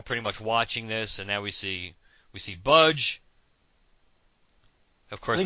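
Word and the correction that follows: B'dg: I think it's Badge. – Oh, that's right. I keep forgetting how technically we're supposed to pronounce it. Just remind me once in a while. So it's Badge.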